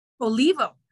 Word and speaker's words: Olivo: Did I pronounce it right? – Olivo, actually.